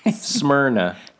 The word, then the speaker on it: Smyrna: What is the pronunciation? Smyrna. – New Smyrna Beach. Smyrna. Pinch your nose when you say it. New Smyrna.